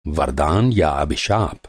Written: वरदान

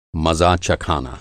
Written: चखाना

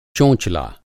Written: चोंचला

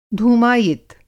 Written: धूमायित

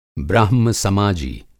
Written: ब्राह्म समाजी

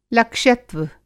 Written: लक्ष्यत्व

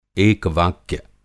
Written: एकवाक्य

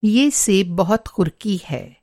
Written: क़ुरक़ी

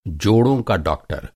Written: जोड़

